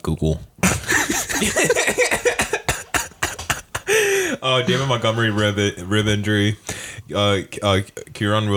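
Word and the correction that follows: Kyren: Williams. Did I say his name right? Kyron Williams, my bad.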